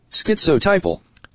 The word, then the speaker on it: Schizotypal: Schizotypal.